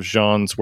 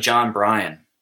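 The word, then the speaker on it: Jon Brion: I'm assuming you're also a fan of Jean's work. – I love that you just, so it's actually John Brion, but I got to tell you, that's exactly how I pronounced it too.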